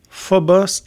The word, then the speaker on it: Phobos: Maintenant, la planète Mars, deux lunes, Phobos et Deimos.